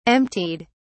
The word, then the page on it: emptied: British English